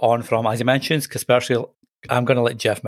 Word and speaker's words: Kaspersky: Kaspersky, yeah, you did.